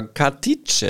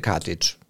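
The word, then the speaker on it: Katić: Bei mir murmelt es gerade so ein bisschen im Kopf immer, wenn ich mit Nikola Katic, er hat uns doch in der Mixzone gesagt, dass er so ausgesprochen wird. Und jetzt nennen ihn trotzdem alle Katic.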